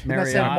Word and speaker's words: Mariota: Mariota? Marcus Mariota. Mariota. – That's correct, it's right? It's Mar like Bar. – Right. – Or Michael Mar.